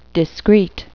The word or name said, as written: discreet